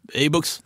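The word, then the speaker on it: e-Boks: Eller hur ska du uttala på danska? – E-box.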